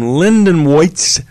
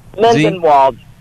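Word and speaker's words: Lindenwold: But right now, I want to go to Lindenwoitz, New Jersey. Is that the way you pronounce that town? How do you pronounce that? – There we go. – Lindenwald.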